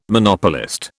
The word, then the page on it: Monopolist: British English